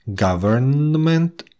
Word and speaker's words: government: Когда в беглой речи мы слышим эти слова, буква N просто съедается. Government, environment, entertainment. Она не произносится вот э, уж точно в слове government, environment.